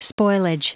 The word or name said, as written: spoilage